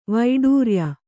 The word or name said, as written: ವೈಡೂರಿಯ